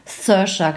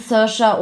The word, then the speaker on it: Saoirse: Saoirse, glaube ich.